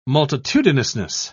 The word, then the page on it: multitudinousness: British English